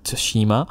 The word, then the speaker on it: Tsushima: Ghost of Cinema. I don't know how you say it. – Look, I stuffed up the pronunciation, so I will give you that. 1 point to Shannon. It was Ghost of Tsushima. I'm going to say that's how we're doing it.